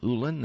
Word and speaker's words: Ulin: It's called Sidewalking, and it's by David L. Ulin, that is spelled U-L-I-N.